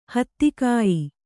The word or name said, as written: ಹತ್ತಿಕಾಯಿ